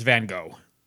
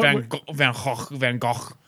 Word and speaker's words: Van Gogh: It's Van Gogh, okay? Not Van Gogh.